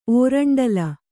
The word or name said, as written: ಓರಂಡಲ